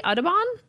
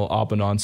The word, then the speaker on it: Audubon: Audubon? And the chances of seeing one are about 1 in 10,000. – So it is extremely rare. – Yeah. – We didn't know how to say the National Audubon Society, so Audubon.